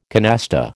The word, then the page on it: canasta: American English